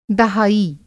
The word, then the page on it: दहाई: دہائی